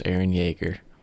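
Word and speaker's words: Eren Yeager: The main character's name's Aaron Yeager. That's how they say it.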